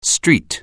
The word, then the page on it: street: anglais